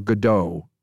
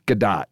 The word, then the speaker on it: Gadot: And she just like, now there's a debate about whether you pronounce it Gadot or Godot. Gal Gadot, I think is what I'm told. – Gadot? – Gadot. – All right, well, we'll go with Gadot. – Like, you know, Sadat.